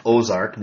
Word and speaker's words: Ozark: Ozark, well, originally was spelled A-U-X-A-R-C, but pronounced Ozark, meaning the bend.